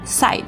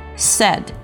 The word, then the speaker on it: said: 第三个单词 said，这个单词呢其实非常简单，但是呢很多人会把它读成 said 或者是 side，注意字母 ai 组合啊，虽然看起来有点迷惑，但是它就发一个的音，开口一直宽非常短促的发 said said said。